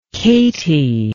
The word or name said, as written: katy